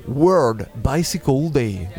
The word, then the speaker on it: World Bicycle Day: Et puis le lundi 3 juin, voilà un événement international, ça sera la deuxième édition du World Bicycle Day. Je le dis bien, non ? Yes. Je prononce bien ? – C'est pas mal.